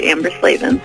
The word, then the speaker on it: Amber Slaven: Did I say that correctly? I just asked you a second ago. – Yeah, no, that's totally right, Amber Slaven.